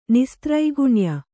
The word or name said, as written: ನಿಸ್ತ್ರೈಗುಣ್ಯ